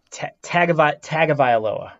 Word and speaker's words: Tagovailoa: The second quarterback on the board, Tua Tagovailoa. Did I pronounce that right? Hold me accountable. – Tagovailoa. I think that's how Tagovailoa. – they usually say it.